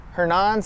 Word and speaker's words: Hernan: Hernan Cias. That's H-E-R-N-A-N-S-I-A-S.